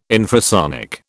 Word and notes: infrasonic: British English

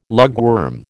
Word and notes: lugworm: American English

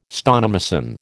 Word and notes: stonemason: American English

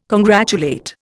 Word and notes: congratulate: Indian

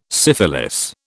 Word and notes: syphilis: British English

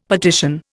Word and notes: petition: Indian

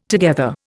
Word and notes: together: Indian

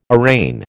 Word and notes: arraign: American English

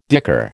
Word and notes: dicker: American English

